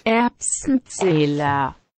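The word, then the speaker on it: Erbsenzähler: Erbsenzela.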